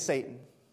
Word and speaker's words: Satan: His name starts with an S and rhymes with Baton. The name is Satan.